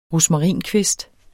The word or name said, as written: rosmarinkvist